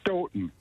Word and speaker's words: Stoughton: You actually, the second time you said it right, Stoughton. They pronounce okay. it Stoughton. – Like Houghton, well, Michigan.